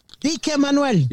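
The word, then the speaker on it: Dikke Manuel: ¿Cómo se pronuncia? – Manuel el gordo. Dike Manuel. – Dike Manuel. Yeah. Dike Manuel. D-I-K-K-E.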